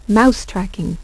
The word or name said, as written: mouse tracking